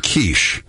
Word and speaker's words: quiche: This morning, I got to tell you, this morning I got up first thing in the morning, had a little quickie. – Wait, is this a what? – I'm sorry, a little quiche.